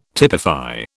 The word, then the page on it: typify: British English